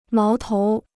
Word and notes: 矛头: máo tóu

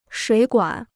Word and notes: 水管: shuǐ guǎn

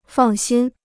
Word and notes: 放心: fàng xīn

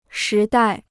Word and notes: 时代: shí dài

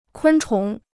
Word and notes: 昆虫: kūn chóng

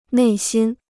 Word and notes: 内心: nèi xīn

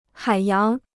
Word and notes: 海洋: hǎi yáng

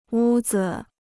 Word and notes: 屋子: wū zi

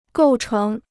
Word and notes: 构成: gòu chéng

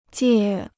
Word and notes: 戒: jiè

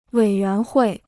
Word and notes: 委员会: wěi yuán huì

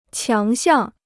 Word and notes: 强项: qiáng xiàng

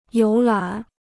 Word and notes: 游览: yóu lǎn

